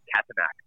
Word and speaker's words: Katanak: which is K-A-T-A-N-A-K, Katanak.